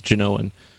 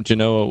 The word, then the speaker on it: Genoa: Actually, Columbus was Genoan. So there was no such thing as Italy back in that time. In other words, you know, someone from Genoa would... Genoa, I'm sorry, I mispronounced it.